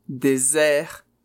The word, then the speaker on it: désert: Bon, il est fréquent qu'il y ait une erreur de prononciation entre désert et dessert, qui signifie sobremesa, dessert. Alors attention, le son z, désert, c'est le deserto. Et le son s, dessert, c'est-à-dire sobremesa.